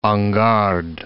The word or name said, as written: en garde